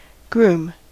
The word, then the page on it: groom: American English